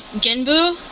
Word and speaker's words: Genbu: I have to correct your pronunciation right there. Because actually, these are based on gods of Chinese mythology. – Right. – And so first we have Genbu, which is the giant turtle of the north.